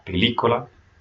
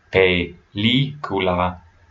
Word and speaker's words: película: So when you see those, or like a word like película for movie, there's an accent on the I, película, make sure you stress that sound.